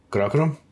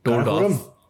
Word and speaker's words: Karakoram: Karakoram? No. I say Karakoram. – Yeah. – And I have been told off that this is a diaspora way of saying it. Karakoram hota hai. – Karakoram.